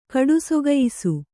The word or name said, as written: ಕಡುಸೊಗಯಿಸು